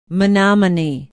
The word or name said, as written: Menomonie